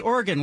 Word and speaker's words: Oregon: You got to say Oregon, like, you know, a piano.